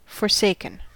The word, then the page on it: forsaken: American English